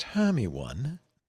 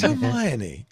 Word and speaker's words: Hermione: It is not pronounced Hermione. It's Hermione.